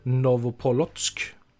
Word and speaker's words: Novopolotsk: Sen bytte han lag under kommande säsong 06-07 till Naftan Novopolotsk. Det är ganska perfekt uttal om jag får säga det själv. Fortfarande inhemsk klubb.